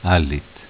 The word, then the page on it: Allit: dialetto